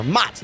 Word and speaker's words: mozzarella: Now, the American way, at least the way I think that 99.7% of Americans say it, is mozzarella with a T in there. Mots. Not spelled with a T, but mots.